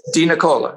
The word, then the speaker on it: DeNicola: Hey, how do you pronounce John's last name? I'm Italian. I would call it John DeNicola. – Well, that's it. – Is it? – DeNicola, yes. – Okay, great. I've heard other people pronounce it DeNicola. I'm like, what's that? – That's stupidness. That's American. – That's American. Yeah, exactly.